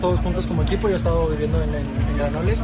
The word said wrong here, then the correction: Granollers: como te digo, Granoles, ¿o cómo se pronuncia? – Española. – Granollers.